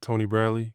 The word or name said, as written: Tony Bradley